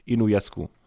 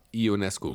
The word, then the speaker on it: Ionescu: Steph Curry gegen Sabrina Ionescu. Ionescu. Ionescu. Das habe ich, glaube ich, letzte Woche schon falsch gesagt.